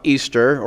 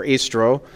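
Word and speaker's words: Ēostre: And that is that Easter is based on an ancient goddess whose name is pronounced just about Easter or Istro, and is worshipped in England at the beginning of spring.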